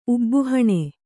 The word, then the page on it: ಉಬ್ಬುಹಣೆ: kannada